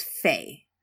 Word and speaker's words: Fe: So I dug deeper and learned that the chemical symbol for iron is pronounced as Fe in the US and UK, but is the spoken letters in Canada.